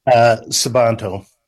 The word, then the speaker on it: Sabanto: Are we talking about Sabanto or Sabanto? I want to make sure and say the name right, sir. – Sabanto.